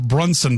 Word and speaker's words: Bronson: And Texter writes, Ryan, Lake Bronson is actually pronounced Brunson by the locals. You might want to change your pronunciation.